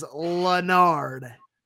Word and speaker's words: Larned: It's spelled Larned, and we were, I was definitely corrected by someone in the chat that it is Lunard.